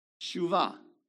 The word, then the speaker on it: shuvah: How do you say turn back in Hebrew? Shuvah. That's correct.